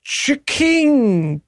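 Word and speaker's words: Ch'King: And Burger King, as a part of that war, has introduced the Chiking Chicken Sandwich. – Damn it. – That is C-H apostrophe K-I-N-G. That's what they went with for the name of their sandwich.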